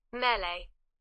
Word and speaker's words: Melee: Melee war jetzt die, die britische Aussprache und die amerikanische Aussprache? – Melee.